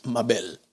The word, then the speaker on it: Mabel: And one of the most popular and beautiful names in Spanish is Mabel, but of course, it's a very different pronunciation. It's Mabel, right?